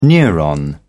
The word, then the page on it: neuron: British English